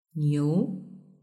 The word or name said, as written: niu